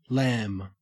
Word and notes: lam: Australian English

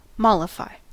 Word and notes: mollify: American English

